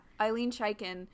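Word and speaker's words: Ilene Chaiken: So this script was co-written by Eileen Chaikin. I believe that's how you pronounce her name. Let me look at it real quick. Yeah, Eileen Chaikin.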